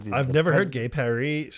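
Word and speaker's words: gai Paris: Gay Paris meeting. It's gay Paris, French pronunciation.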